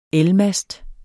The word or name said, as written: elmast